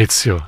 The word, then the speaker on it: Ezio: Ezio.